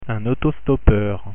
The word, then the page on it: autostoppeur: French